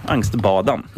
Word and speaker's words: Angstbadan: Filip Karlström, han är en frontfigur i bandet Angstbadan, tror jag man uttalar det. Eller är det Angstbadan? – Badan ska jag nog säga i så fall. – Angstbadan, yes.